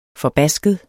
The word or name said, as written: FORBASKET